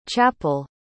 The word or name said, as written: chappel